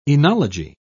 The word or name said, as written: enology